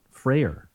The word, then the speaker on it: Freyer: If it was F-R-A-Y-E-R. – Right. I've always thought of it as like Friar Tuck. – Right. – But it's not. – No. – Freyer. – Yeah. – Interesting. Nobody in your family pronounces it Friar? – Absolutely not. – Wow. – In fact, we've corrected people for many, many years.